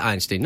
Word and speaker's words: Einstein: If it was an actor like Bob Einstein, I'm probably saying his name wrong. I can see it in my head as Einstein. – Einstein? Yeah, I think it's Einstein.